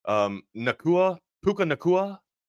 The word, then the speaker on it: Puka Nacua: I mean, I had fantasy exposure in both of them, so I needed Puka Nacua.